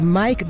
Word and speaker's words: MMYYKK: very soulful artist that goes by the name of Mike, but it's spelled M-M-Y-Y-K-K, into Dante Elefante, who will be taking the school night train to the airliner this week, May 14th.